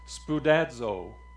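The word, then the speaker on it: spoudazo (σπουδάζω): It's pronounced spoudazo. Isn't that cool? Spoudazo.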